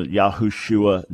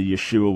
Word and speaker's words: Yeshua: Even their mispronunciation of the Lord's name, you know, Yahushua nonsense, as opposed to Yeshua, which his name was,